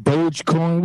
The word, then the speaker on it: Dogecoin: If you got, what was it, Dogecoin, Dogecoin, what is it? – It's both. It all depends on how you say it. – Man, I call it dog coin. You got a dog up there, we call it dog coin, man. The D is silent.